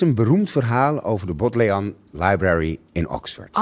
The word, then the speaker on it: Bodleian: Maar ehm, het is eigenlijk Bodleian. Let even op de klemtoon.